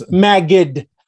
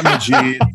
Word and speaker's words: Magid: And pronounced it's Magid. – Magid, not Magid, not Majid, not Magid, none of those things.